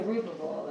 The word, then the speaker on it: Zerubbabel: And we have Zerubbabel, right? – Zerubbabel, that's right.